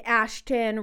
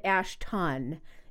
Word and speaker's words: Ashton: So instead of using an S-O-N name, use a T-O-N name like he had, you know, said Ashton Son, especially because you don't pronounce it Ashton. You say tin, at least in the U.S.